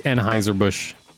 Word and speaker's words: Anheuser-Busch: Pippin settled with Anheuser-Busch and Playboy in April 2006. – Uh, it's Anheuser-Busch. I know it's spelled totally different, but that's what it is.